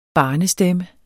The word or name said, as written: barnestemme